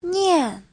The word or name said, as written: nian4